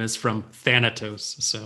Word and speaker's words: Thanatos: Thanatos. – Is that how you say it? Yep. So I thought that was very interesting.